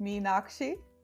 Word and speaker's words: Meenakshi: My first name is pronounced Meenakshi.